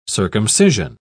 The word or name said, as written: circumcision